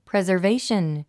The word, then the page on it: preservation: prèzərvéiʃən; プレザベイション